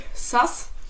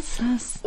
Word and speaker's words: SAS: Nee, also Auflösung SAS wird es ausgesprochen. – Sass.